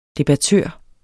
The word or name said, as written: debattør